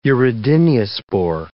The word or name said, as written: urediniospore